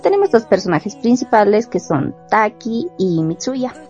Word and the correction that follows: Mitsuha: Mitsuha, Mitsuha.